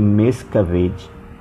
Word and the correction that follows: Miscavige: acho que é assim que se escreve, acho que é assim que se pronuncio o nome dele, Miscavige,